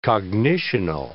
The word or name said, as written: cognitional